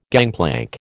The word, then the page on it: gangplank: American English